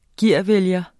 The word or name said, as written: gearvælger